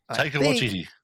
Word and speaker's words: Taika Waititi: And how do you pronounce? I'm sorry, I'm really bad with his name. – Taika Waititi. – Yes.